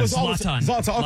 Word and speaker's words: Zlatan: How you pronounce the guy's name? I always mess his name up. Ibrahimovic. Help me out. Zoltan. – Yeah, I used to call him Zoltan. – Zlatan. Okay. Zlatan.